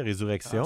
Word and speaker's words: Resurrection: Parlant de bonne musique, il y a Resurrection. Est-ce que je prononce en anglais ou en français? Resurrection. – Ouais, en français, Resurrection.